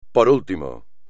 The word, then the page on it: por último: espagnol